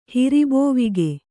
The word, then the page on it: ಹಿರಿಬೋವಿಗೆ: Kannada